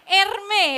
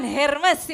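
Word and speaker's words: Hermès: Erme ya, bukan Hermes ya kan.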